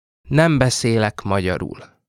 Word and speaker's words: nem beszélek magyarul: This phrase in Hungarian is nem beszélek magyarul. Nem beszélek magyarul.